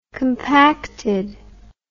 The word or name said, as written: compacted